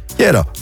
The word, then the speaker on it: hierro: Eindigt een Spaans woord op een klinker N of S, dan valt de klemtoon op de voorlaatste lettergreep. Dus in dit geval, hierro. Nee, ro.